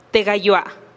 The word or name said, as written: te gayoa